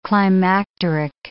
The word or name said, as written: climacteric